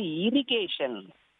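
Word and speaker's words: irrigation: முதலாவது இரிகேஷன். இரிகேஷன் என்றால் நீர்ப்பாசனம். உச்சரிப்பு சொல்லி கொடுக்கும் போது மாணவர்களுக்கு சிறுவர்களுக்கு சொல்லிக் கொடுக்கும் போது ஷன் ஷன் என்று முடியும் என்று சொல்லுவேன். டிஐஓஎன் என்றாலே டியான் கிடையாது, சன் என்று சொல்லுவேன்.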